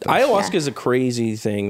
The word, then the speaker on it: ayahuasca: Ayahuasca. – Ayahuasca. – Ayahuasca.